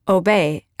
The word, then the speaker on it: Aubais: pronounced Aubais, as in love, honor, and Aubais.